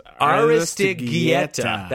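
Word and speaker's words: Aristeguieta: But Fernando Aristigueta? Guieta? No, wait, hang on. – Yes. – Aristigueta. That's how you say it.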